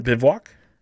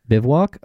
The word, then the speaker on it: Bivouac: We've got, well, how do we say this? The team, Bivouac? – Bivouac. – Bivouac, okay, is coached by Gary Payton, and he has Kings legend Corey Brewer on this squad.